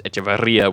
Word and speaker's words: Echeverría: He's Antanasio Echeveria. The plant is Echeveria, which only has one R, but he is Echeveria with the two R's in the accent on the I.